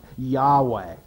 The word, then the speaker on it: Yahweh: Most likely, at least if we were to think in terms of modern Hebrew, the pronunciation of YHWH would be something like Yahweh.